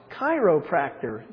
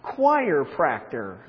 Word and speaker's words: chiropractor: By the way, it's pronounced chiropractor, not choirpractor.